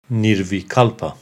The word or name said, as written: Nirvikalpa